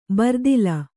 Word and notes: ಬರ್ದಿಲ: bardila

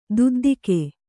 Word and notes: ದೊದ್ದೆಗ: kannada

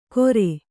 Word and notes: ಕೊರೆ: Kannada

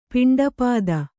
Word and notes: ಪಿಂಡಪಾತ: Kannada

